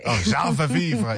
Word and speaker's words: Ah, je veux vivre: Ah, je veux vivre.